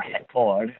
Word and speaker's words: Record: Interestingly, now one of the main television stations, Record, or Record, as it's pronounced in Brazilian Portuguese, is owned by an evangelical pastor turned billionaire.